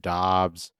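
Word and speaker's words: Doubs: Romeo Dubes or Dubs, I I still can't remember how we fucking pronounce that shit. Dobbs.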